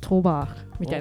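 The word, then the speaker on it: trobar: トロバール、これでもなんて発音するの、いやちょっと当時の発音だとトバーみたいな感じじゃないですか、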